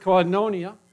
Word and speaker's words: koinonia: And while I do not speak Greek, I do not know Greek, I've heard preacher after preacher pronounce that word koinonia.